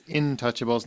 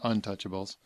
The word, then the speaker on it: Intouchables: said, wait, and it's Intouchables, not Untouchables. Brian. Hugh. – You're the one who said it wrong.